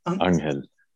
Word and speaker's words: Anghel: Angel. – Angan. How do you spell it? Spell it for me. – A-N-G-H-E-L. – Angel.